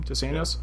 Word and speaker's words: Dos Anjos: Is that how you pronounce his name? Dos Anjos? Yeah.